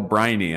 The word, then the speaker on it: Briney: I'm doing very well, and we are here today with Carol Briney.